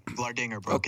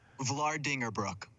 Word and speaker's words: Vlaardingerbroek: Vlaardingerbroek. It's Dutch. Yeah. – say it again. I'm going to try. – Vlaardingerbroek.